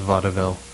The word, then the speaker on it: vaudeville: People who pronounce vaudeville as vaudeville are okay in my book. – Hmm. Yeah, I'll take it. – That extra syllable just says they're that cool.